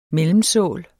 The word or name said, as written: mellemsål